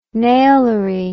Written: nailery

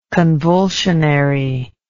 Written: convulsionary

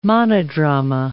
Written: monodrama

